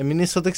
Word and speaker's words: Minnesota: É Minnesota que se pronuncia, né, Jonathan? – É, ah, tá. É assim.